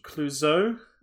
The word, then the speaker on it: Clouzot: Um, so next week we are doing uh, the French director Henry Georges Clouzot. Is that how you say his name? – Yeah.